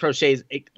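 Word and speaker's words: Proche: He wasn't going to drop the ball, and that's what I really liked from James Prochet. And I think Prochet's like how you pronounce his last name. Heard from a couple of people that's how you're pronouncing it.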